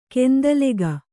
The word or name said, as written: ಗೊಂದಲಿಗ